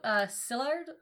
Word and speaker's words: Szilard: Leo Szilard, I think is how you say it. S-Z-I-L-A-R-D. – Yeah, I'm pretty sure it's Szilard. – Like S-Z, right? – Yeah.